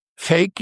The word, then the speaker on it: PHEIC: Fake ist P-H-E-I-C. Das heißt Public Health Emergency of International Concern. Wird Fake ausgesprochen.